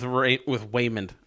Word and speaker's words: Waymond: And it's contrasted a lot with Waymond. I almost corrected to Raymond. It's Waymond.